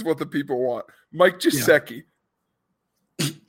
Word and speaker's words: Gesicki: It's Gesicki. Hard G.